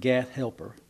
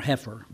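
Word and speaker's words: Gath-hepher: the prophet who was from Gath-Helper, or Hefer, depends on how you say it.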